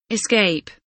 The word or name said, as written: escape